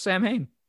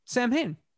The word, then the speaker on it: Samhain: I prefer the real Ghostbusters pronunciation, which is just Samhain. – Samhain.